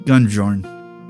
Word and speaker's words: Gunnbjorn: Marshal Bloodsong works closely with Captain Gunborn or Gunjorn? Gunjorn? It's it's spelled G U N N B J O R N. So I'm gonna call it Gunjorn. Yeah, that's how we're gonna say it.